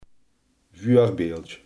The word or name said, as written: voorbeeld